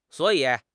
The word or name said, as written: suo3 yi3